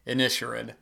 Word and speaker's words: Inisherin: You know, on a side note, I didn't even realize till recently, I've been calling the Banshees of Inisherin the Banshees of Inisherin. I heard somebody else say that, and I had to actually look it up, how it's pronounced, and it is in fact pronounced Inisherin. So